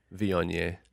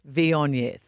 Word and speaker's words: Viognier: Viognier. – Viognier, thank you.